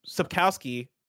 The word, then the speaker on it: Sapkowski: In Sapkowski, the W is pronounced like a V. So Sapkowski. Yeah, we're learning today.